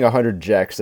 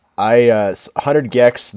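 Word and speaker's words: 100 gecs: I was saying 100 Jex at the show, but that's obviously wrong. – Oh, who knows? – But 100 Gex, they were the first opener.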